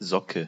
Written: sock

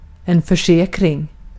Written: försäkring

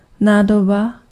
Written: jar

